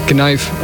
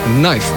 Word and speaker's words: knife: Het is ook geen knijf als je een mens bedoelt, maar het is een knife.